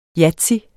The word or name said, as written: YATZY